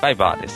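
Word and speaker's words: Viber: はい、バイバーでいいんですかね、読み方は。 – バイバーですね。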